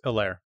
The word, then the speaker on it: Helaire: It's Helaire. – Clyde Edwards-Helaire. I can't say Helaire. – I think it's Helaire. I mean, I'm not French, but I think that's how it's pronounced.